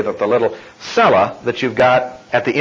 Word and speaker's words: Selah: which is cella. That's transliterated, by the way, because that's the Hebrew, way the Hebrew word is pronounced.